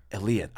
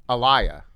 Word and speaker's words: Aaliyah: Aaliyah. I knew it was that. – Yeah. – How often does that happen? – Well, you know, I used to pronounce it wrong myself. I used to say Aaliyah.